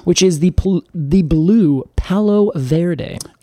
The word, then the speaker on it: Verde: Well, you kind of said it correctly in Spanish, because the V in Spanish is more like a B sound. – Really? – Verde.